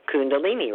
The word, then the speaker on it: Kundalini: Kundalini could be spelled with a C, couldn't it? If it was spelled C U N D A L I N I, we'd pronounce it just the same. We'd say Kundalini, right? – Yeah.